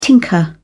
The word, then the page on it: tinker: British English